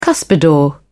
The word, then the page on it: cuspidor: British English